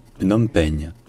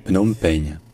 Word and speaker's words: Phnom Penh: Donc en francisant le mot, tout en restant assez proche de la prononciation en khmer, ça devient Phnom Penh. Phnom Penh.